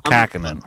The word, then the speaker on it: Kähkönen: Kaapo Kähkönen. – I'm going to ruin it. Kähkönen.